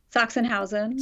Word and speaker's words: Sachsenhausen: And then there's this whole backstory about how the cows are from, forgive the pronunciation, is it Sachsenhausen? – Sachsenhausen.